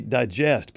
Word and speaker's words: digest: I just realized Red is trying to say digest, but he keeps saying digust. – Yeah, he does. He said digest three times. – Three times. Okay, Red is spelled D-I-G-E-U-E-S-T.